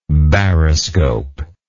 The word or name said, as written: baroscope